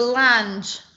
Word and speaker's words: Lange: L-A-S-E. – Are you sure it's Lange? Like Jessica Lange. – Jessica Lange. – Yeah. Okay. I might have said it wrong. I said it the British way, the Lange. – Well, considering that the Lange Foundation, Adopt a Pet, is one of our sponsors, we want to make sure we really get it right. – Yes, which how do I say Lange? – Well, I would pronounce it Lang.